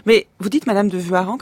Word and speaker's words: Warens: C'est ce que ça s'écrit W-A-R-E-N-S. – C'est d'abord W-A, c'est une faute de transcription déjà en Savoie, parce que le vrai nom de famille c'est V-U-A, donc c'est Vua, et puis en Savoie, Reims se dit Rang.